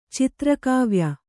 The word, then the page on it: ಚಿತ್ರಕಾವ್ಯ: citrakāvya